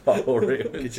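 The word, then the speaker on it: Fjällräven: Fall Raven stores. – Is that actually how you pronounce it? – Yeah. – Fjall Raven? Yeah, I guess.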